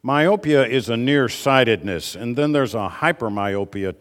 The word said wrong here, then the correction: hyperopia: type of situation. Hyperopia, I think, is the way it's pronounced.